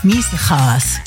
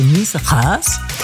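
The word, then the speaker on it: Miesegaes: Stanley August Miesehaas hieß der. Ich habe mal gehört, ein G spricht man im Niederländischen wie Ch, also Miesehaas.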